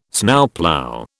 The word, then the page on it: Snowplough: British English